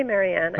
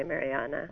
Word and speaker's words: Marianne: Marianne, do you pronounce it Marianne or how do you pronounce it? – Yeah, I say Marianne. Okay. I think in German you'd say Mariana.